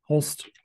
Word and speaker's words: Holst: Holst. Okay, perfect. Holst.